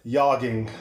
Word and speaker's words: Jorma: I'm pretty sure it's like Jorma. – I think it's something. I think it's Tack One. Just kidding. No. – it's yogging. It's like a soft J.